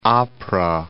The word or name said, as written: Apra Harbor